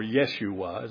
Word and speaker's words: Yeshua: Now, you know that Jesus is the New Testament form of the Old Testament name Joshua, or Yeshua, as they pronounce it.